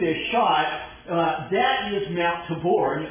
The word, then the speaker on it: Tabor: That is Mount Tabor, is how the Hebrews pronounce it. Spelled T-A-B-O-R. We've got a street named that not far from here, too. Tabor, I think, is the way most of us pronounce it. But Tabor is how it ought to be.